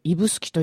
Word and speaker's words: 指宿: しかも指宿と書いて指宿と読む、もう皆さんご存知ですよね。